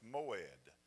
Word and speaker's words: moed: The word feast, translated feast into English, is a Hebrew word, and it's pronounced moed.